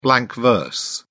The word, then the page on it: blank verse: British English